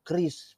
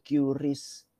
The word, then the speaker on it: QRIS: Apa ya pemerintah ini kadang-kadang ya Orang pakai ngejanya dibacanya Chris bukan Curis.